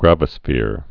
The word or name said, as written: gravisphere